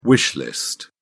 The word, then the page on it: wish list: British English